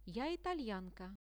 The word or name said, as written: ja italianka